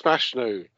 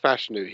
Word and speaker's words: Fashanu: He's really good, and it's pronounced Fashanu. No matter what some Americans seem to want to pronounce it, it is pronounced Fashanu. He says it that way. That is the way he wants it pronounced. We know how to pronounce the name Fashanu over here.